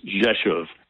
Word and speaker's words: Rzeszów: That was at a place called called Rzeszów, which is Rzeszow, pronounced Rzeszów, and it starts with RZ.